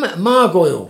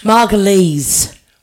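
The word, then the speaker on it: Margolyes: Miriam Margoyle. – Margulies. – Well, there you are. You know who she is. I deliberately mispronounced it, so you'd correct me and say I knew you knew who it was.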